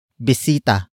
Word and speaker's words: bisita: It is the focus marker that introduces the main topic of the sentence, and that is bisita. Bisita. Bisita. Bisita is spelled as B-I-S-I.